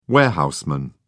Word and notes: warehouseman: British English

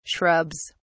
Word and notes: shrubs: British English

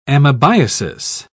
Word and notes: amebiasis: British English

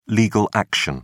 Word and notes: legal action: British English

